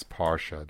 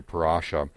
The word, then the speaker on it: parsha: Uh, the English pronunciation is parsha. The the Hebrew pronunciation is closer to parasha,